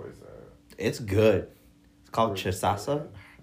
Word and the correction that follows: cachaça: I said it. I butchered it. I'm so sorry. Cash cachaça.